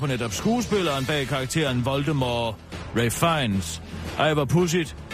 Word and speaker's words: Voldemort: Rowling øh, har tidligere under interviews fortalt, at den sidste stavelse i øh, Voldemort, som skal, øh, skal udtales som det franske mor, som betyder død.